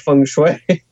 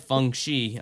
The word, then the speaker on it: feng shui: Sounds a lot like feng shui. That's why I'm getting confused. – I'm telling you, they said feng shi. – Well, I think they mispronounced it. They might have met feng shui.